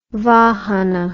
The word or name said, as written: vahana